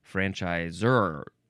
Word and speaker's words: franchisor: Yeah, you'd probably just say franchisor. Zor? Anyway, you are supposed to emphasize that last syllable.